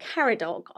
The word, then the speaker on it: Caradoc: I've wanted to put Caradoc on a name list for so long. So it's C-A-R-A-D-O-C, Caradoc.